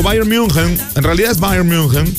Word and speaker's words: Bayern München: Bayern München.